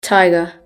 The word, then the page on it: tiger: British English